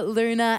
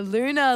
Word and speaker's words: Luna: Hey, my name is Anika Luna. Anika, that rhymes with sneaker. Luna, like the moon.